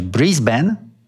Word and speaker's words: Brisbane: Otóż tubylcy szkolą na początku pobytu turystów, aby wymawiać Brisbane lub Breeze Vegas.